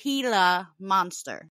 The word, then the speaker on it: Gila monster: I'm saying it wrong. It's a Gila monster. Thank you, Sherry. Sherry looked up the pronunciation, so it's pronounced Gila monster.